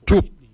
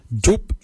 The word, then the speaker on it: djob: For kidney, we say jup. Jup is spelled D J O B. Jup. Jup is your kidney. Lungs, we say wal. Wal is your lungs. Wal is spelled W-A-L-Y.